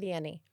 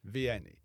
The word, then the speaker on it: Vianney: St. John Vianney. – Vianney.